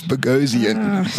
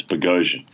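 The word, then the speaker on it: Boghossian: It's Bogosian. – A Bogosian. Well, I'm sorry. I can't pick up nuances like this. – He actually told me you pronounce it like explosion, but with a B. So it's Bogosian.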